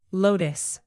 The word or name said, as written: lotus